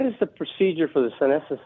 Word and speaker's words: senescent: So the reason I'm asking about the senescent cells, is that correct? – Yeah.